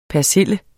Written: persille

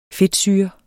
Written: fedtsyre

